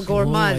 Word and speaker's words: gourmand: No, but it's spelled G-O-U-R, like gourmand. M-A-command. – Yes. – So gourmand.